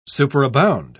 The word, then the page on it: superabound: British English